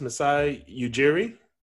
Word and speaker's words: Masai Ujiri: But how do you pronounce this? Masai Ujiri? – Yes, you got it right.